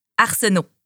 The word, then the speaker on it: Arsenault: Arsenault. – Comment ça s'écrit? – A-R-S-E-N-A-U-L-T.